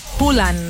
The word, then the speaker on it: Haaland: Se trata de Haaland, o como descubrí que se pronuncia, Haaland.